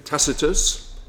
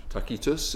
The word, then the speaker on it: Tacitus: The historian Josephus, who was Jewish, and the senator and historian Tacitus, who was Roman, or Tacitus, if you prefer the Latin pronunciation, they both wrote of the crucifixion of Jesus under Pontius Pilate.